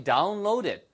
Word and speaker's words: download it: 그래서 where can I download it? Where can I download it? 아시겠죠? 발음은 D 발음이 뒤에 모음 사이에서 A하고 이렇게 있기 때문에 발음이 리스로 바뀝니다. Download it처럼 바뀌어요.